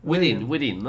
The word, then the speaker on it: within: ¿Cómo se pronuncia Within? – Pues siempre. Vale, within, ¿no? – Sí.